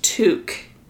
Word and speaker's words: toque: It was spelled T-O-Q-U-E. It was pronounced Took in the movie. I would have pronounced it Toke or Tuck.